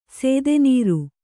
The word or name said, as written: ಸೇದೆನೀರು